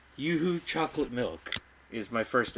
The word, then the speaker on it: Yoo-hoo: I'm pretty sure that's pronounced Yoo-hoo, and you just said Yahoo. Yes, there. You said Yahoo.